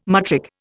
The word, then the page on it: matric: Indian